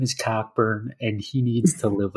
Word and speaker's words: Cockburn: Kofi, everybody says his name is Coburn.